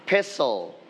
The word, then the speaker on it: pestle: อะไรสักที่ it's silent t it's silent we don't pronounce t okay this one you can say pestle together pestle